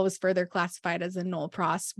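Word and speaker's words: nolle pros: And we learned, I think it was our last story where we learned about the thing I can't pronounce and that you said is just Nolpros, just called Nolpros in the biz.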